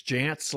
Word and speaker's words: Jantz: It's Jantz like pants, but it's spelled really weird.